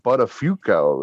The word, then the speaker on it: Buttafuoco: And he comes out of the booth and Dave Foley's just like, you know that name's pronounced Buttafuoco, right?